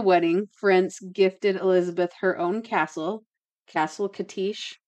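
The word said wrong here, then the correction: Čachtice: Kashtis, sorry, I said it wrong.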